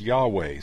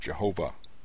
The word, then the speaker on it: Yahweh: His name is Yahweh, sometimes pronounced Jehovah.